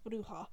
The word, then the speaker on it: Bruja: Okay, I'm 100% certain that's pronounced Bruja. Because it's the Spanish Bruja. – Word for witch.